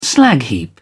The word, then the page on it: slag heap: British English